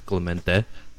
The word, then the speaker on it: Clemente: My name is Clemente Espinoza. If you want to say my name right, it's Clemente.